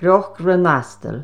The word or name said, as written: CNOC RHAONASTIL